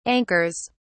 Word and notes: anchors: British English

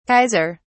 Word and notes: kaiser: British English

